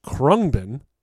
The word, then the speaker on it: Khruangbin: The band is pronounced Krungbin, which is Thai for airplane. And that's spelled K-H-R-U-A-N-G-B-I-N.